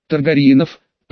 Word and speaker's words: Таргариен: Таргариенов, поправила блондинка, сделав ударение на предпоследнем слоге.